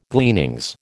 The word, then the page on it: gleanings: American English